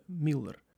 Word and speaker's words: Мюллер: Міллер забив на 33-й після вкидання за бокової. Е, так, хоч е, писали його прізвисько Мюллер, вимовляли бразильці саме Міллер.